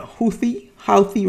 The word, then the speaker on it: Houthi: You got the war in Yemen, where the rebels, the Houthi rebels, that's how you pronounce it, against the Saudi government, the U.S.